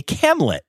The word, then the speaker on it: camlet: a camlet, which I really like and rhymes with Hamlet.